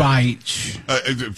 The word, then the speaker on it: Beich: Because we were trying to pronounce, I was trying to pronounce a reporter's name, and it's spelled like, bitch. Something like, it's like that. – But it's pronounced exactly that. – Beich. – From what we understand, right, it's pronounced Beich.